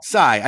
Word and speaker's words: Tsai: Tsai. I think the T is silent.